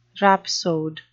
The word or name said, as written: rhapsode